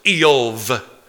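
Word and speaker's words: Eov (איוב): It's actually pronounced Eov. And the other one, Yovav.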